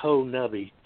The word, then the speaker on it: Honobia: And I got to learn to pronounce it right. It's Honobia to the according to the locals. I've been saying it wrong since I've been here.